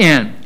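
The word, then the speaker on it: en (ἐν): That preposition in the Greek is en. It's pronounced en.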